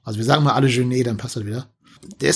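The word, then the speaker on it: Jeunet: Können wir uns auf einigen? Genet wird ausgesprochen. Genet. – Genet. – Okay, okay, okay.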